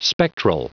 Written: spectral

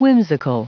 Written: whimsical

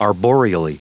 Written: arboreally

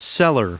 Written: seller

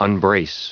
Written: unbrace

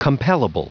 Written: compellable

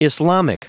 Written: islamic

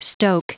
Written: stoke

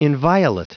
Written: inviolate

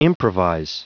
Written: improvise

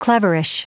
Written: cleverish

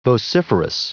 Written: vociferous

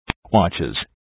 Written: watches

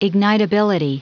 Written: ignitability

